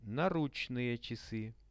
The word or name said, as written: naruch-chasy